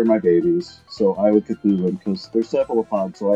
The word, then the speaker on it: Cthulhu: How do you pronounce it then? Cthulhu. Ah, well done.